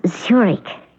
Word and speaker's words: Zurich: Won't you have a chair, Mrs. Zurich? – That's pronounced Zurich. as in the Swiss city.